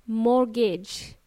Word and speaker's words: mortgage: We pronounce it as mortgage. T is silent. Yes, there is a T in this word. Mortgage, M-O-R-T-G-A-G-E.